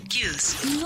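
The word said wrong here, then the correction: Kyuss: No se dice Cuse, se dice Cayuse.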